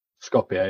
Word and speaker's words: Skopje: Is it Skopje? Is that how you pronounce it? – Skopje, yeah.